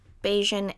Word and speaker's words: Bajan: And the Bajan culture, and Bajan is how you say a person from Barbados, in case you do not know.